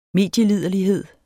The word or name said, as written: medieliderlighed